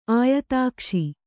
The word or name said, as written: ಆಯತಾಕ್ಷಿ